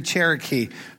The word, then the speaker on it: Gerecke: His name was Henry Cherokee. Now, his name is pronounced that way, but it's spelled G-E-R-E-C-K-E if you look it up sometime.